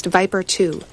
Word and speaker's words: VIPR2: Scientists focused on the gene for VIPR2, pronounced Viper2, a receptor for one of these neuropeptide chemical messengers.